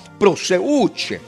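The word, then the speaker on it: proseuche (προσευχή): La palabra oraciones, amados, en la palabra griega se pronuncia proseuche y se refiere a una oración constante,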